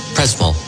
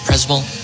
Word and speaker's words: Przemyśl: In could you pronounce exactly where we? It's Przemyśl. Przemyśl. This town where we are. – This Przemyśl. – Przemyśl. I'm sorry. Przemyśl. I pronounce it completely different.